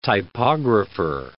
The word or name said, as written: typographer